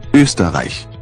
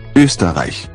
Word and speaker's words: Österreich: The German word for Austria starts with this letter, which sounds a little like U. – Ö. Österreich. Österreich. Österreich.